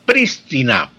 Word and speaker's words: prístina: estaba la palabra prístina, prístina, y hacía énfasis en la pronunciación esdrújula, esdrújula, con acento esdrújulo y no grave, como lo usan incorrectamente la mayoría de personas en el habla corriente y popular.